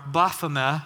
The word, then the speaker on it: Baphomet: What comes to mind for most of you, I'm assuming, is like a cartoon imp in red pajamas, or probably this guy, which is Baphomet, or Baphomet, if you want to get technical with the pronunciation.